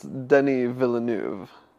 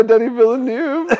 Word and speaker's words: Denis Villeneuve: Because it's French, and it's spelled Denis Villeneuve. – Right. – But they don't say it like that, and no one... The only other person that I know who knows these kinds of things says Villeneuve. Villeneuve.